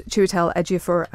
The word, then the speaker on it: Chiwetel Ejiofor: He is, of course, Chiwetel Ejiofor, and he was talking to Nick and Phil.